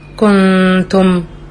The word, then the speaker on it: كنتم: لما بقول كلمة كنتم، أنا ما بخليش للسان عمل فيها في الغنة، لكن بعض الناس بتقراها شوية بتقعر فبتضم، فبتقول كو كو، يعني بتجيب واو بدل غنة كنتم، فبتقول كو كنتم، أو منكم مي، أنا بقول منكم منكم مش منكم.